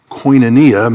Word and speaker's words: koinonia: That's their K, their O, their I. That what looks like their V is our N. What looks like their W is our long O sound, another N-I-A. Koinonia means sharing fellowship, participation, or communion.